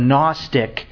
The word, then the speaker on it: Gnostic: St. John was writing at a time where the Gnostic heresy, and you can all hear the silent G there, the Gnostic heresy was rampant.